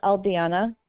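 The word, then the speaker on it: Aldijana: It's Aldiana.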